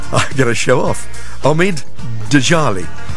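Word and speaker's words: Omid Djalili: The comedian, and I can never pronounce his name right, but I think it's Omid Djalali. That's right. Oh, I got it right.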